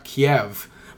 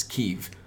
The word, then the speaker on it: Kyiv: The news very often says Kiev... But that's apparently the Russian pronunciation. Mm-hmm. So, like, Ukrainians get really fucking pissed off when you say that. They don't get pissed off, but they, like, immediately correct you. And the correct pronunciation is Kyiv. I don't know what dialect Ukrainians speak. Probably some sort of, like, Slavic language. But it's Kyiv.